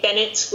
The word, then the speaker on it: Bennett: Letter R, letter K, Bennett with 2 N's and 1 T.